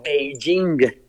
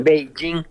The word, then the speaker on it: Beijing: P-E-K-I-N, uh-huh. Cuando la capital es China se pronuncia Beijing, sí. – Beijing.